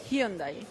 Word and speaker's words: Hyundai: La pronunciación es con J, Hyundai.